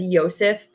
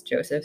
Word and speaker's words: Josef: Okay, so it's also pronounced as Yosef, but American version is Joseph, so I'm just going to I mean, you can't even pronounce American words most of the time.